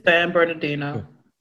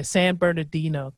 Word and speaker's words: San Bernardino: San Bernardino. Yeah, San Bernardino.